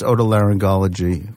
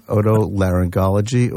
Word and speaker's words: otolaryngology: So that's otolaryngology or otorhinolaryngology, depending upon Wait, say it again. Otolaryngology or otorhinolaryngology.